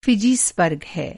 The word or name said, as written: फ़िजी